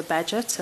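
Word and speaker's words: Bagehot: The guy who said it is called Walter Badgett, and I can confirm that is how it's pronounced because I looked it up from multiple sources.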